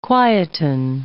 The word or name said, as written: quieten